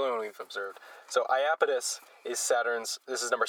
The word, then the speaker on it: Iapetus: How is this one pronounced, Flo? – Iapetus. – Iapetus.